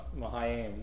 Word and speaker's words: Mahanaim: Mahanaim.